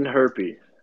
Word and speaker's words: Hjerpe: I'm reading Herpy. oh, okay. Connor Hjerpe. Yeah. That's what I've heard.